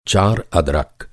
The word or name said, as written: अदरक